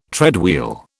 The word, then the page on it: treadwheel: British English